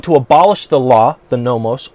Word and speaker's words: nomos: The word that we see in the New Testament that is used to describe the Mosaic Law is nomos, N-O-M-O-S. Pronounced it nomos.